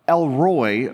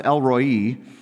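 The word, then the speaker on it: El Roi: So we're looking at Genesis 16, and it's the name, some people translate or say it, Elroy, but I believe it's pronounced Elroi, but it means the God who sees me, which is a beautiful thought, even just in the name itself.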